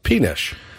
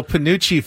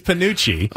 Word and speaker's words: penuche: But if it's sweet treats you're after, today is also National, and how would you pronounce this word? P-E-N-U-C-H-E. – Penish. – Today is National Panucci Fudge Day. – Panucci? – And I wasn't sure exactly what... – Is it Panucci? – It is Panucci.